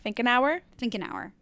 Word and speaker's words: Finkenauer: Finkenauer.